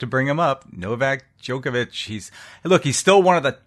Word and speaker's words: Djokovic: Yes, Djokovic. The D is silent.